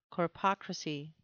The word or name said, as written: corpocracy